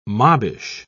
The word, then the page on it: mobbish: British English